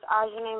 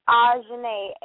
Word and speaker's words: Ajahnae: Spell your name so I pronounce it correctly. – Ajane, A-J-A-H-N-A-E. – Okay. A-J-A-H-N-A-E, Ajane, got it.